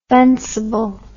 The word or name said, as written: fencible